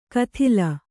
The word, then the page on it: ಕದಲ್: kadal